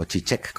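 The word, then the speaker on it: Žižek: Yo creo en algo que dice Zizek, el filósofo, o Chichek, como le dicen algunos, yo le digo Zizek porque lo pronuncio como se escribe.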